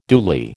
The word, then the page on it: duly: American English